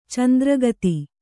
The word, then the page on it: ಚಂದ್ರಕಿತ: candrakita